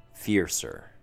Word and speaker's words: fiercer: Fiercer. Two syllables. Let's break it down. Fear sounds like deer, D-E-E-R, but with an F in front instead. Fear. Sir has the typical E-R ending with a S sound in front.